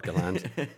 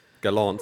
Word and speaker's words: Gallant: sustainable, but it looks like Gerhard Gallant, or as you... – Gerhard Gallant, yeah. – He knows what he's doing over there, so... – I mean, he's a really good coach, Mr. Gerhard Gallant. Gallant, sorry.